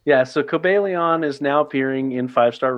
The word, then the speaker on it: Cobalion: So it is pronounced, according to my book, Cobalion. So I did say it basically right. Cobalion.